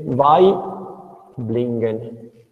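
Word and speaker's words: Waiblingen: Velf e vai Blingen.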